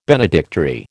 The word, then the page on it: benedictory: American English